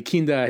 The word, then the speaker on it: Kinda: Gadi Kinda has got the funny name, Kinda, because it's spelled like kinda.